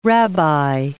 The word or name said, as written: rabbi